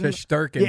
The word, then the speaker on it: Shesterkin: Shesterkin.